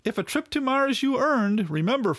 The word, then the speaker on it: Moers: The company gave a trip to him and his wife to Mars, Germany. It's spelled M-O-E-R-S, but it's pronounced Mars.